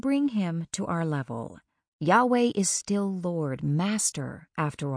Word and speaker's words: Yahweh: When you see all caps L-O-R-D, it represents an ancient Hebrew spelling of Y-H-W-H. It has no vowels, so when we try to pronounce it, it sounds like Yahweh or Jehovah.